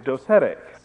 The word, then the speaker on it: docetism: That's spelled, as you've seen in perhaps in the signs, D-O-C-E-T-I-S-M, and it's pronounced, according to the dictionary, as a noun, docetism, but as an adjective, docetic.